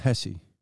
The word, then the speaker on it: Hsieh: It's pronounced Hesse. – It might be. I think it's the, I think one of those Zappos. – Letters, one of those letters are silent. H-S-I-E-H.